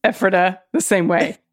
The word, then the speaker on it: Ephrata: It's Ephrata. – Ephrata. – Emphasis on emphasis on the first syllable. – Ephrata.